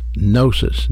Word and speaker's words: gnosis: Normally, this Greek word would be pronounced gnosis, G-N-O-S-I-S. However, it is not the word used here.